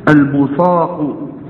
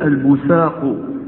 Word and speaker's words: البصاق: يجوز أن تقول البصاق، البصاق بالصاد، البصاق في المسجد خطيئة. ويجوز أن تنطق هذه الكلمة بالسين، تقول البساق، البساق بالسين. ويجوز فيها أيضا لغة ثالثة وتكون بالزاي، البراق. إذن هذه ثلاث لغات كلها جائزة في هذه الكلمة.